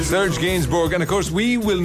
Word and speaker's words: Gainsbourg: So the song from your youth is this one by Serge Gainsbourg. That's how you pronounce it, isn't it? – Yeah, Gainsbourg, yeah. – I would have said Gainsbourg, but it's Gainsbourg.